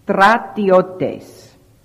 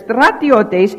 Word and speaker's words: stratiotes: Second reason, Matthew 28:12 refers to these guards as, in the Greek, stratiotes. Now, that is the correct pronunciation.